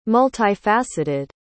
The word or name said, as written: multifaceted